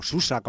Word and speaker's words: Sousse: La casba de Sousse, que Sousse es otra ciudad muy turística o Susa, como dicen allí, Sousse, se escribe con doble S, y que allí también pues estuvieron rodando parte de esas escenas callejeras que hay en la vida de Brian.